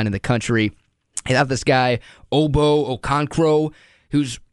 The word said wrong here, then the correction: Okoronkwo: Okoronkwo, Okoronkwo, whatever, however it's pronounced.